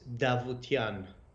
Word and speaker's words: Davutian: So my name is it's pronounced D, the first two letters. So my name is Davutian.